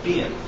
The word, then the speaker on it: Behan: My name is Jeremy Behan, and I. – Behan. – Behan. It's Irish.